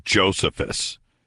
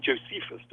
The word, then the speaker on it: Josephus: In America, you call him Josephus, don't you? – Right, right. – Well, the name comes from Joseph, so I call him Josephus. – Josephus. Josephus Flavius. – It's pronounced in the Greek as Josephus.